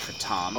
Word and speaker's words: kratom: I briefly listened to a podcast kratom is pronounced kratom. Oh, kratom, yes. Because it originates from Thailand.